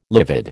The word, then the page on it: livid: American English